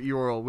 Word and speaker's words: Eorl: Yeah, you can find all this about Fram and his ancestors and stuff in Appendix A, the House of Elrol, Eorl. – Yeah. – Sorry, I'm not good at pronouncing stuff. – No, it's okay. – House of Eorl, which is in the Appendix A of Return of the King.